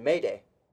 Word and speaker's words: Madej: And I said, hey, man, M-A-D-E-J, how do I pronounce this name? And he said, Mayday. So Tyler Mayday, spelled M-A-D-E-J.